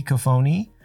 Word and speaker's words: Cofone: You got the like actual Italian pronunciation right. So here in the US, it's just Cofone.